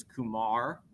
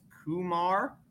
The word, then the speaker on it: Kumar: So the way I say his name is Kumar Rocker with the accent on the second syllable. That is approximately how he says it. It's been kind of, I guess, southernized as Kumar.